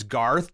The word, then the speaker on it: Garthe: Garth is spelled with an E. It's Garth, just like you think it is, and then with an E.